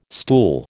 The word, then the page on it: stool: American English